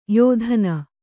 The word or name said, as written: ಯತ್ನ